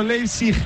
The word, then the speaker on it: Leipzig: Que ese tifo simulando un aeropuerto destino Leipzig, ahora sí lo he dicho bien, está muy chulo.